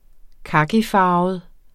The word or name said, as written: kakifarvet